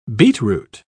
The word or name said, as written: beetroot